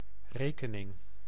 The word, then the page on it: note: France; /nɔt/